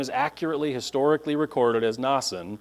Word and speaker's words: Mnason: This is a super common name. Every time the name shows up, it's Nason. It's an A sound in there. It's Nason. But the Greek here is definitely not. It's an A. It's Mnason.